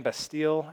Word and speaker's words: Bastille: Have you guys heard of that band Bastille? I think is how you say it.